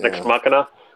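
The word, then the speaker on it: Nex Machina: Oh, it's called Next Machina. Next Machina? I don't know how to say it. NEX? – Machina. – Machina. – Machina. Yeah. Next Machina.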